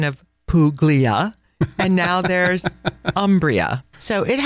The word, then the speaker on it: Umbria: So, you know, before I carry on, I just want to mention the pronunciation of the word Umbria, which is normally, or sometimes I should say, mispronounced by English-speaking people, and they call it Umbria.